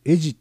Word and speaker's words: Ed: エジっていうのがね ED ED と書いてエジって発音するんですよね。